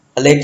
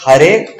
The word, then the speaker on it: Hare: And but in bhakti you see the Chinese cannot pronounce ra. It's not there in the system. So when they chant Hale Krishna, Hale Krishna, Krishna Krishna, Hale Hale, Hale Lama, Hale Lama, Lama Lama, Hale Hale. Matlab, all 16 syllables are wrong. All 16. Not even one is pronounced properly. Because Hare, Krishna, Rama, all have ra. And they can't pronounce ra.